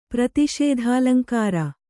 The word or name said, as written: ಪ್ರತಿಷೇಧಾಲಂಕಾರ